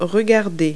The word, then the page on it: look: verb